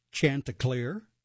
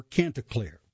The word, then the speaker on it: Chanticleer: Actually, the name can be pronounced either way, so I'll give you them both. Chanticleer or Canticleer remains at the vanguard of choral ensemble excellence in America, Baldwin Auditorium.